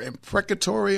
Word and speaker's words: imprecatory: What is the proper way to say that prayer? Imprecatory or impeccatory? – Imprecatory.